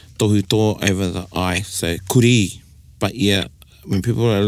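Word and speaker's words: kurī: So you can hear at the iwi the stress in the kuri. So the second one is the correct way to say kuri, which is the Māori word for dog. And how you would write that is K-U-R-I. With a macron over the eye, or tohuto.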